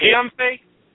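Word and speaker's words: Guillaume Faye: But what stuck out to me was I'm probably mispronouncing his name, Guillaume Fay, the author of... – Is it Guillaume Fay? – Yes.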